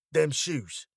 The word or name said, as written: them shoes